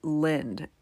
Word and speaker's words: Lynd: This party is in Lind. It's a town. It's spelled L-Y-N-D.